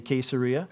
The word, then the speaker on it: Caesarea: For those of you that get annoyed when I say Caesarea, Google, in fact, agrees with me.